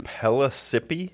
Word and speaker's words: Pellissippi: I was thinking Pellissippi? – Pellissippi State. It's spelled like Mississippi, only with a P.